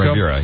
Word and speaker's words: Ribera: Ribera, yes.